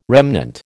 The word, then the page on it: remnant: American English